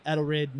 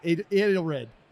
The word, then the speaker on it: Edelrid: Vice President of Edelrid North America. Did I say it right? Edelrid. Oh, shit. – Edelrid. – I messed up.